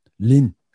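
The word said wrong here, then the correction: Lytton: Lin diye mi okunuyormuş? Biz Litton diye veriyorduk sanki. – Litton. Pardon, tamam. Yanlış söyledim ben. İyi ki düzelttin. Litton.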